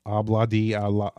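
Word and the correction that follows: Ob-La-Di, Ob-La-Da: Obla di obla da.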